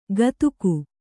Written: ಕದುಕು

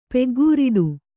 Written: ಪಕ್ಕಾಖರಡೆ

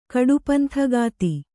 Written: ಕಡುಪಂಥಗಾತಿ

